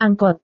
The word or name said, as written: angkot